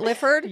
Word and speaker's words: Lifford: Am I saying that right, Lifford? – Yes, like Clifford without the C.